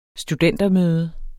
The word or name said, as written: studentermøde